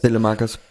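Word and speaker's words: Saelemaekers: OG kam für Solemarkers oder Selemarkers. Keine Ahnung, wie er ausgesprochen wird. – Selemarkers. Das ist alles noch nachvollziehbar? – Jetzt wird's wild.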